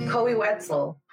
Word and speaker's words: Koe Wetzel: Um, the song is called "Love" by Kobe Kobe Wetzel. I don't know if I'm saying his name properly. Koe Wetzel, I on Spotify.